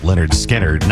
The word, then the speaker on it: Lynyrd Skynyrd: Skynyrd celebrating yesterday the anniversary of the album that put him on the map, pronounced Lynyrd Skynyrd.